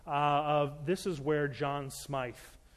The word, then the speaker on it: Smyth: And usually the person there will look at you and go, we didn't have a John Smith here, but we have a John Smyth. So we pronounced it John Smyth. So in case you're having dinner conversation and you want to sort of show off, it's John Smyth.